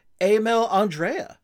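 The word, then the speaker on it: Emil Andrae: But the one that people were really talking about was, and I didn't even know his name was pronounced like this until I saw a clip the other day, Emil Andrea, what I thought was Emil Andre. – Okay. I still don't know what the pronunciation is because I think we are talking about this in the Slack chat and I think it was Maddie Someone said that it's Andre. – That's how they pronounced it on the clip I heard that NHL had linked to.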